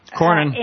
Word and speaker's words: Cornyn: Cornyn.